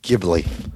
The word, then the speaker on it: Ghibli: And I believe it's pronounced Ghibli. – Ghibli.